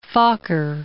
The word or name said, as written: Fokker